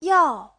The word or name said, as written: yao4